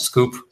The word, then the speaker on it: SKWP: It's SKWP. Pronounced Scoop.